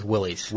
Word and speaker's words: Willys: There was a 38 Ford, and there was one from Wiley. Am I saying that right? – I think it's Willys.